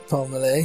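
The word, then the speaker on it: Pelmelay: Do you pronounce it Justin Palmele? – Yeah, Paul Malay, yeah.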